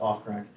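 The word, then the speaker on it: Ophcrack: I think it's actually pronounced off crack.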